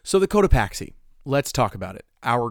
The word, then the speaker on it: Cotopaxi: The Cotopaxi. – Cotopaxi.